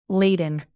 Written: laden

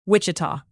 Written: wichita

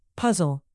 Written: puzzle

